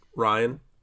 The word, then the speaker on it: Rian: Star Wars Episode 8, The Last Jedi, is the 2017 film directed by, how do you pronounce his name? Ryan or Rian? – Ryan. – Ryan? – Yes. – Well, he needs to spell it like Ryan.